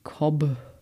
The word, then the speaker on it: Cobh: Geschrieben wird das Ganze aber C-O-B-H, also wie Cobb.